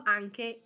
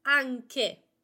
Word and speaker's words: anche: Anche. The emphasis is on the first A. Anche. We have a hard K sound. Anche. K, K, anche. Because of the letter combination C and H.